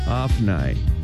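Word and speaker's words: Ophni: 먼저 오브니를 영어로는 이렇게 발음합니다. 잘 듣고 따라해 보시기 바랍니다. OPA가 있어요. PH가. 그래서 아프나이. 한번 더요. 아프나이. 오브니를 아프나이 이렇게 발음하시고 게바는 그대로 게바 G-E-B-A 게바 이렇게 발음하시면 되겠습니다.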